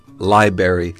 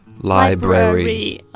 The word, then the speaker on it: library: Right, and some Americans I've heard mispronounce it before as library, like strawberry or blueberry. Yeah, it's a library. – 尤其在读快的时候非常容易发生这个样子，这样其实就是少了音节。所以 again, 正确的读法应该是 library.